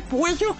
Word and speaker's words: Puello: Se escribe con doble L, Puello.